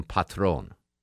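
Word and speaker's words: Patrón: But anyway, so I'm watching the show, and they called Pablo Escobar, the people that worked for him, they called him Patron. Right? Am I pronouncing it right, Deb Green? – Yeah, right. Just like the tequila.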